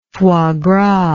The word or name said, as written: foie gras